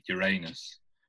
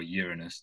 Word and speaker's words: Uranus: Uranus or Uranus, depending on how you pronounce it, with like the little at symbol in the middle.